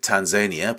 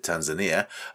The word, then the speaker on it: Tanzania: This is from a guy from, as he pronounced it, Tanzania, but I might have pronounced it Tanzania, Saidi Kanda and Muvuala Bandondo.